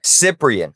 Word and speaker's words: Cyprian: It's not Carcigen. That sounds bad. How do you say that guy's name? – Cyprian.